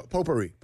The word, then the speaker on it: potpourri: P-O-P-O-U-R-R-I. – So close, but incorrect. – Was there a U in there? – There's a silent T in there. – P-O-T. – P-O-T. – What? A T? – P-O-T-P-O-U-R-R-I. – It's not potpourri. – It's silent T.